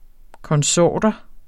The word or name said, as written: konsorter